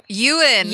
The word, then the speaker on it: Ewan: Ewan. – Ewan. – Like a sheep. – The E is silent, I think. – Ewan? – Ewan. – Ewan. Okay. – You win. – You win.